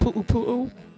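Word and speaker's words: pū pū: But, did you know poo-poo platter is actually pronounced poo-poo-poo? Poo-poo-poo. P-U-apostrophe-U. P-U-apostrophe-U.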